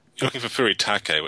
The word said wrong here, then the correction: furikake: I've only ever heard of it Furikake, K-A-K-E.